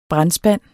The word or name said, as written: brandspand